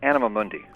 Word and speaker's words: Anima Mundi: Hey, our own Anima Monday. – That's right. It is I. – Did I say it right? – Yeah, almost. Anima Mundi.